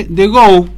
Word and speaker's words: DGO: Y la aplicación se llama, se dice The Go, se escribe DGO.